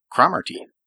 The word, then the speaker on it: Cromartie: By the way, and so my relatives will not be upset, the last name is actually pronounced Cromartie. – Cromartie? – Scotland. – Oh, you're all those NFL football players who pronounce it Cromartie are actually wrong. – Ah, okay. So here I thought you had a great link to a great linebacker, but Cromartie. Okay. And that's the proper Scottish pronunciation.